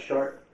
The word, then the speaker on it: Chartres: The big church place at Chart, is it? Crystal? – What? – The cathedral is. It's pronounced Chart.